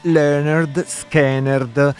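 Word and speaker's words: Lynyrd Skynyrd: Allora, mi chiedono la pronuncia di un gruppo di South Rock barra blues barra hard rock straordinario, è scritto Leonard Skinnerd e si pronuncia Leonard Skinnerd.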